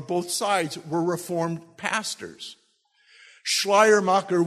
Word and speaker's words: Schleiermacher: Everybody say Schleiermacher. And you have to say it where you almost have to wear a mask because you're going to spit it out, good German pronunciation.